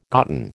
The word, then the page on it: gotten: American English